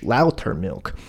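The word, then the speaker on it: Lautermilch: It's L A U T E R M I L C H, Lauter milk.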